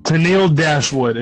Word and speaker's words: Tennille Dashwood: Tennille Dashwood is how you pronounce her name.